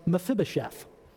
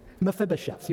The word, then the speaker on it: Mephibosheth: His name was Mephibosheth. That's how you pronounce that. Mephibosheth.